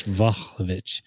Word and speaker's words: Vlahovic: Vlahovic.